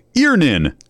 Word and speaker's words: Earnin: Earnin. E-A-R-N-I-N is pronounced Earnin. I don't know how else you would pronounce it, but they really want it clear that that's how to pronounce it.